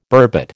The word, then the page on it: burbot: American English